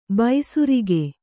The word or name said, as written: ಬೇಸರಿಕೆ